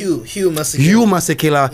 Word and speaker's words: Hugh Masekela: And that is the Mojave Kotu tribute to Nelson Mandela with Hug Masekela. I hope I said it right. – Hugh Masekela. Yeah.